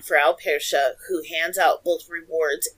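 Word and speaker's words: Frau Perchta: Yeah, it's spelled P-E-R-C-H-T-A. But the C-H-T-A is soft, so it's Frau Persche.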